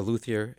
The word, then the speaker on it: luthier: What is a luthier exactly, and am I saying it correctly? – Yeah, it's a luthier, and it's a person that makes string instruments.